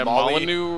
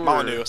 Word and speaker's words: Molyneux: Peter Molyneux. – Yeah, Molyneux. – Molyneux. Sorry, yeah. – Molyneux, I think.